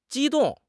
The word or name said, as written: 机动